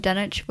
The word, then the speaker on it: Dunwich: But yeah, so the Dunwich city of Dunwich was a medieval city that was like one of the biggest well, it was the biggest city in East Anglia.